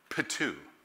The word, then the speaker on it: ptuo: The word is transliterated into English, spelled this way, P-T-U-O, and it's pronounced patu.